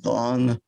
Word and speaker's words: thon: In 1884, a very famous American hymn writer, Charles Converse, coined the pronoun thon, T-H-O-N. And he said, I got it by blending that and one, and it should be pronounced thon.